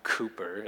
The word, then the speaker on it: Cowper: His name is William Cowper. It's spelled like Cowper, William Cowper.